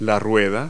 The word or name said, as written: wheel